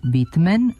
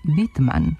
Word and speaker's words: Whitman: Recimo, prezime američkog pesnika Walta, autora čuvene zbirke pesama Vlati trave, jedni izgovaraju Whitman, a drugi Whitman.